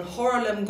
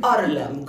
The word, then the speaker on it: Harlem: Eh, tillsammans med sex stycken damer från Harlem Gospel Choir, eller Arlem Gospel Choir säger man i Italien, för man vägrar uttala H. Det går man kan inte andas ut. Det går inte på italienska.